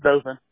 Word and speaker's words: Dothan: Is it Dothan or Dothan? – No, it's Dothan.